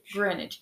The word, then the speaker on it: Greenwich: Greenwich.